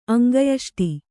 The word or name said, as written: ಅಂಗಯಷ್ಟಿ